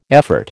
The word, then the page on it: effort: American English